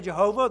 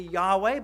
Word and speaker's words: Yahweh: It's always written in English, all capital, L-O-R-D, right? Yah, some people would say Jehovah. The better pronunciation would be Yahweh.